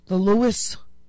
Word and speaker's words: Luas: you can hop on the Lewis. I have to find out what the letters mean of Lewis, because Lewis is spelled L-U-A-S. What is that about?